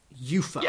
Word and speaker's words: UEFA: UEFA. I'll write down YOOFA. UEFA. – Yes. That would be the pronunciation.